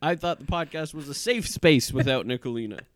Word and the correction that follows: Nicolini: Nicolini, without sorry. Nicolini. I don't want to mispronounce her name on her birthday.